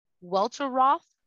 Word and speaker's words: Welteroth: And as Michelle mentioned in the last episode, she watched a really good masterclass by Elaine Welterworth, Welteroth. – Mm-hmm. – That's how you pronounce it? – Yeah.